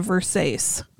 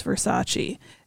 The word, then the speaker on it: Versace: There's this famous scene, of course, in the movie where she tells the people that this dress is a Versace. – Yes. – Because she doesn't know it's pronounced Versace. They choose to kind of titter and laugh and not correct her. But when she leaves and says it's a Versace to Kyle MacLachlan, he's like, it's Versace.